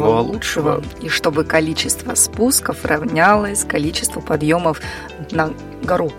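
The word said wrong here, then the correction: на-гора: На гору, гора. Как правильно говорят, на гора.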